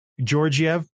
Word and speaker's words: Georgiev: It'll be interesting to see how Georgiev, is that how you pronounce it? – Sure. Georgiev.